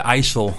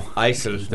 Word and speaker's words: IJssel: The IJssel. – IJssel. – The okay. – River. Yeah. – The IJssel is the big lake at north of Amsterdam, isn't it? – That's the IJsselmeer. The IJssel is a river running from the Rhine to the IJsselmeer Lake.